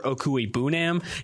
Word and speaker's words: Okwuegbunam: Staying in Missouri, tight end Albert Okwuegbunam.